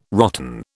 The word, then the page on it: rotten: British English